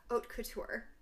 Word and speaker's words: haute couture: Modeling, and I the pronunciation of this word, which I would have called haute couture. – That's the correct pronunciation.